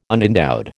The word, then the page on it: unendowed: American English